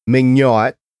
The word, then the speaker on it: Meugniot: His name is Will Meugniot, and that's spelled, by the way, M-E-U-G-N-I-O-T. I did get the pronunciation right, but that's the last time I'm going to say his last name.